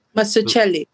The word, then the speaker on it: Mazzucchelli: Mazzucchelli. – Yeah, Mazzucchelli.